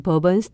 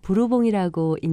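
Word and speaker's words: Bourbon: 예를 들어서 뉴올리언스에는 버번 스트릿이라는 거리가 있는데요. 버번은 프랑스 말로 부르봉이라고 읽는데 이 프랑스의 부르봉 왕조에서 나온 말이라고 합니다.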